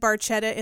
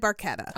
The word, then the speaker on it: Barchetta: He says Red Barchetta in the song, but that is not how it is pronounced. – Oh. – It's an Italian car. It's Red Barchetta. – Oh, it's Red Bruschetta. – Yeah, like you would say Bruschetta. Because it's CH.